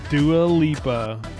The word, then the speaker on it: Dua Lipa: I think that's not breaking any news, cause Brandon could see it, but yeah, that's Dua Lipa. I feel like I'm saying that name wrong every time. – No, that's right. – Dua Lipa.